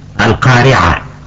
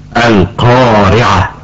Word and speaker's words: القارعة: معناه أن يمتلئ الفم بصدى الحرف عند نطقه، مثل حرف القاف في كلمة القارعة، فالقاف فيها مفخمة، فلا يجوز أن نقول القارعة، فهذا خطأ. أما الصواب أن نقول القارعة، أي نملأ الفم بصدى حرف القاف.